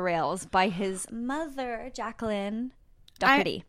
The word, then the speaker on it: Doherty: And in this episode today, we're going to be talking about Pete Doherty. Or I know that I think if you're from London or from England, it's pronounced Doherty. Pete Doherty.